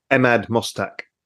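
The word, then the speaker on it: Emad Mostaque: Emad Mostak.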